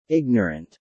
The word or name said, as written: ignorant